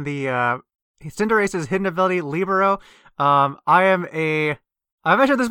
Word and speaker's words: libero: but the word is correctly pronounced libero from the sport.